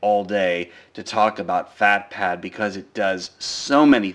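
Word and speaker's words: PhatPad: Today I want to talk to you about a very, very interesting note-taking application called FatPad. That's spelled P-H-A-T pad, P-A-D, that's all one word.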